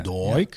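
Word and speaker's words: Doig: Zeg ik het goed? Ik denk dat je een Schotse uitspraak toch? Ja, Doik.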